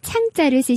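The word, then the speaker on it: 唱: 한자어로 부를 창자를 쓰시고 발음은 위에서 아래로 뚝 떨어뜨리는 사성으로 창 이렇게 발음해 주시면 돼요.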